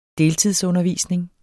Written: deltidsundervisning

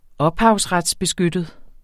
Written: ophavsretsbeskyttet

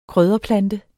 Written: krydderplante

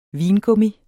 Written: vingummi

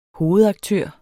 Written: hovedaktør

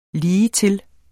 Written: ligetil

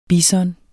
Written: bison

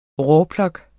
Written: rawlplug

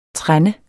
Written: trende

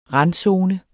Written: randzone

